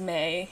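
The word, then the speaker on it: me: So maize is plural. It's spelled M-E and singular form is May, and it's a sacred power / property of gods that allow humans to exist.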